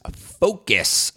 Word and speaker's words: FAOCAS: Well, this episode's going to focus on Experience Curating's FOCUS framework. That is an acronym for the Experience Curating how-to process, and FOCUS, F-A-O-C-A-S, which I pronounce FOCUS, stands for Filter, Archive, Organize, Contextualize, Access, and Share.